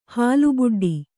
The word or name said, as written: ಹಾಲುಬುಡ್ಡಿ